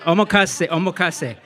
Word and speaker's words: omakase: I ate my way from izakaya to, omakase. I almost forgot, omakase. I was trying to think of the pronunciation, but thank you.